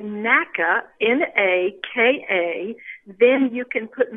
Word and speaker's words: Natchitoches: But you got to get that NACA at the beginning. And the emphasis on that is on that first syllable on the N-A.